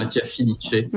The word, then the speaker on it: Mattia Filice: Bon, on peut dire Mattia Filice, mais comme il est d'origine italienne, c'est oui. Mattia Filice.